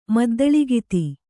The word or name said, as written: ಮದ್ದಳಿಗಿತಿ